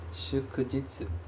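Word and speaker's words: Shukujitsu (祝日): E nós vamos, para fechar o nosso trio dos jitsus, nós vamos ver esta outra palavra, que é shukujitsu. Vou colocar ela aqui também no nosso quadro. Shukujitsu.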